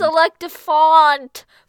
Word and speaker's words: Selectifont: Nope, it's Selectifont. Font! – I didn't hear an O in there, but okay. – There's two O's. – So now it's Selectifont? – No, the O, the second O is silent.